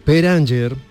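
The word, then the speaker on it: Per Anger: Peranger perdone que yo no lo pronuncie bien en sueco. – No, es eh, correcto, es correcto.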